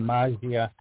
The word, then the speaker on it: Maggia: And I don't know if I'm pronouncing her name correctly, but it's Amelia Molly Maggia. – I would. I'm Italian. I pronounce that Maggia.